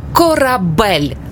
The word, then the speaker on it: корабель: Як правильно, корабель чи корабель? Правильно, корабель. Наголос на «е».